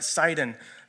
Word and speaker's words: Sidon: And so the first place that their ship goes is up the coast to a place called Sidon, or you might pronounce it Sidon, depending on how you read that.